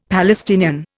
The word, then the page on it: Palestinian: Indian